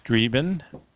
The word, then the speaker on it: streben: And the German word streben, pronounced streben, means to strive.